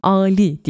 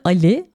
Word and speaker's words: Ali: Bu arada Ali değil Ali diye telaffuz ediliyor.